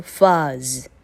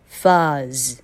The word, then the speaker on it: fuzz: OK, number two, fuzz, fuzz. 好，你听到的是 fuzz, short vowel U, 好，是短母音 U 的发音。